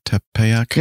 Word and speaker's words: Tepeyac: Okay, so number 4, the significance of Tepeyac Hill, the site of, am I saying that right, Tepeyac? – Yep.